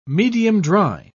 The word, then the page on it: medium-dry: British English